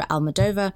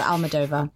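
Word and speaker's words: Almodóvar: Pedro Almodovar. Somebody else was saying Almodovar, but it's actually Almodovar.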